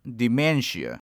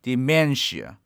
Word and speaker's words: dementia: Notice that the two E's in this word should be pronounced differently, with the first E pronounced as I and the second pronounced as A. Also, the stress is on the second syllable, so dementia, dementia. Last but not the least, our third tip today is the word traumatic.